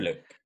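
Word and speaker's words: Kieryluk: Yeah, well, it's by Igor Kieriluk, if I'm pronouncing that right. – Kieriluk, I think you're right, yeah.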